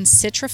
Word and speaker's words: Citra: We are all the way out here in Citra, Florida at Dorksville Farm.